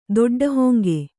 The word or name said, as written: ದೊಡ್ಡಹೊಂಗೆ